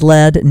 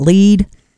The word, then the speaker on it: Led: They dropped the A in lead to ensure people would pronounce it as lead, not lead.